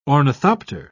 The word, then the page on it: ornithopter: British English